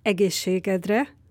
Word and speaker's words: Egészségedre: Und noch ein Beispiel, was die Touristen sagen immer wieder: Vorsicht, wenn du ein Prost sagst auf Ungarisch. Es heißt Egészségedre. Und beim schlampiger Aussprache bzw. die Akzente wegzulassen, dann wird ein lautähnliches Wort Egészségedre und das heißt auf deinen Arsch.